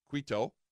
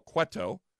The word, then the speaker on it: Cueto: Now look, in hour two, we're going to welcome to the program Border Patrol Agent Art Del Cueto, short E. I got to say that right. Art Del Cueto.